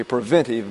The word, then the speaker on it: preventive: So it is this principle that is a restraint and a preventative measure, you see, a preventive measure. You have a tendency to put an extra syllable in that word, but it's not there. It's preventive.